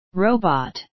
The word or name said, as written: robot